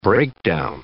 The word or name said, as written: breakdown